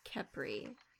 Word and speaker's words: Khepri: Khepri. – Like, yeah, K-H-E-P-R-I.